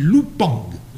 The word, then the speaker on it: Lupin: Et là, on a, donc là, il n'est pas question de Lupin, mais les deux dernières syllabes sur l'affiche, on dit en coréen, on dit pas Lupin, on dit Lupang. Lupang. Arsène Lupang.